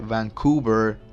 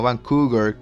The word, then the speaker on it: Vancouver: Me reí un montón con tu crítica porque lo dije bien rápido y dije Vancouver y no, y no es así, es Vancouver. te quedó buena, me retracto, dije mal el, el, el sitio, la ciudad es Vancouver, Canadá, no Vancouver.